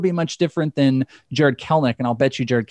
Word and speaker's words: Kelenic: It looks like Kelenic, but it's Kelnick.